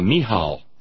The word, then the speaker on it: Michal: These wives are Mishal, Abigail, that's Abigail the Carmel, and Bathsheba, and their stories greatly influence the histories of Israel. Any way you care... Michal. Thank you. That's a silent C.